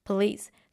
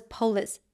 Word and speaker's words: police: That's the word police. So I used to pronounce the word as police. I'm sorry. I don't know where I got that from, but it was polis to me.